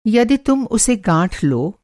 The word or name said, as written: गाँठना